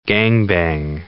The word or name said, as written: gangbang